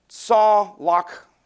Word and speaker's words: tsawlakh: Now, the word prosper in Psalms 1 is the Hebrew word, and that Hebrew word is pronounced in our English language as saw lock. If you spelled it in English to sound like the Hebrew word, it would be spelled T-S-A-W. L-A-K-H, saw lock, a primitive word, and it means to break out.